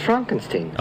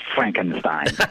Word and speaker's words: Frankenstein: No, it's pronounced Frankenstein. – Okay, Dean Koontz, you give us the answer. Frankenstein or Frankenstein? – It's Frankenstein.